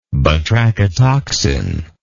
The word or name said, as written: batrachotoxin